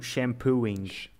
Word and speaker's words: shampooing: È buonissimo, Avec le G, G muet. – Ok, ok. – Bello. – Le G muet. Ah, fica muet. Une lettre muette. Shampooing. Alors que d'habitude, les mots anglais, on prononce toutes les lettres, même en français.